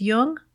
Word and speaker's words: Jung: Just be sure to pronounce Jung with a hard J. So with special thanks to Fisher King Press, you've been listening to Speaking of Jung.